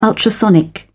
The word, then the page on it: ultrasonic: British English